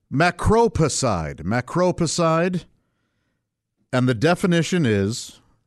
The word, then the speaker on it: Macropicide: Macropicide. It's macro, M-A-C-R-O, picide, as in he was beside himself. Oh, that's ridiculous. P-I-C-I-D-E. But it's pronounced macro. The emphasis is on that second syllable there.